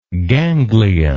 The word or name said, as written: gangliar